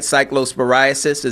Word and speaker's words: Cyclosporiasis: Cyclosporiasis.